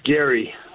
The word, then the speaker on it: Gary: It's actually Gary. Hi, Dave. How are you? – Good, Jerry. Am I getting your name right? – No, it's Gary with a G.